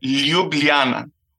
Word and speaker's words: Ljubljana: Okay, Ljubljana. – Ljubljana. – No, it starts with L. Ljubljana.